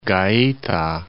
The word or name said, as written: Gaeta